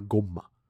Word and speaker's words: Gomah: Se ve idéntico a él y vive en el reino demoníaco y es el asistente de este, se llama Goma. – ¿Goma? – Goma. – ¿Como de mascar? – Ajá. Pero se escribe G-O-M-A-H. Goma.